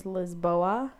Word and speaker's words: Lisboa: Like Lisbon is Lisboa.